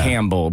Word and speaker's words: Campbell: Then the other one, C-A-M-B, like yeah. Campbell. Because you say Campbell, like the P is kind of silent.